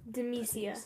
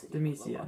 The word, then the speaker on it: Demacia: D-E-M. – D-E-M. – E-A-C-I-A. – E-A-C-I-A. How do you pronounce it again? – Demacia. – Demacia.